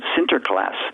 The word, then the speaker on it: Sinterklaas: They pronounced it Sinterklaas. And the American, the other people around it weren't Dutch. They didn't really understand the Dutch accent. It sounded like Santa Claus.